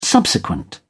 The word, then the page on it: subsequent: British English